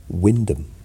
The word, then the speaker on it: Wymondham: There's a place in um, near Norwich, Wymondham, Wymondham, Wyndham.